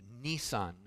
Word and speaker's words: Nisan: So the name of the month that the Passover was to be celebrated on was the month of Nisan, not like the car, okay, but it's pronounced the same way.